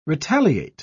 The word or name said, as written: retaliate